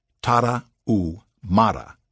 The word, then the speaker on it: Tarahumara: The Tarahumara, pronounced Spanish style by swallowing the H, Tarahumara, may be the healthiest and most serene people on earth, and the greatest runners of all time.